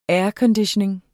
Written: aircondition

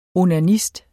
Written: onanist

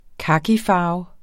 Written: kakifarve

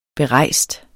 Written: berejst

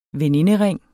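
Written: veninderinge

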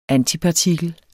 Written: antipartikel

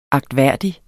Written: agtværdig

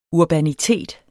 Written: urbanitet

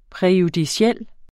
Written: præjudiciel

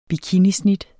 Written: bikinisnit

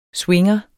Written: swinger

